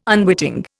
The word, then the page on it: unwitting: Indian